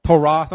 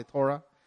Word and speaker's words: Torah: Am I pronouncing that right? Torah. People say Torah. I say Torah.